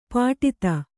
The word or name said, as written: ಪುಟಿತ